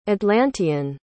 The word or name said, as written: atlantean